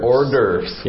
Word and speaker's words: hors d'oeuvres: Hors d'oeuvres. – Yes, and again, this is a French word, and when you read it in the dialogue, it doesn't look like the way I'm pronouncing it.